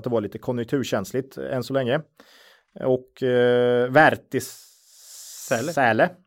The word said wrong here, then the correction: Wärtsilä: Wärtsilä heter det förresten, Wärtsilä.